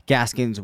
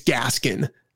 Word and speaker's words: Gaskin: I think it's Gaskin. And yep. not Gaskins.